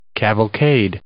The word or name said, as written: cavalcade